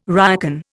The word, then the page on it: ryokan: Indian